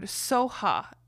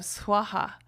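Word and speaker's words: svaha: Soha, spelled S-O-H-A. And so I had to go looking. I'm like, why is that? And it's just because of differences in pronunciation with the Buddhists. They just pronounce the words differently because of their accents or whatever it is. Swaha is one of them. They pronounce soha.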